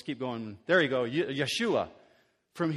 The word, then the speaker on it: Yeshua: If you were going to pronounce it in Hebrew the way you've heard it before, how would you say it? You would say Yeshua.